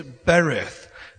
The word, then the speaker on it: berith: The word covenant in the Hebrew word is berith. B-E-R-I-T-H is the way you would spell it if it was in English.